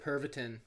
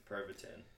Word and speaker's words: Pervitin: Pervitin. – Pervitin.